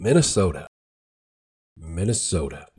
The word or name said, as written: Minnesota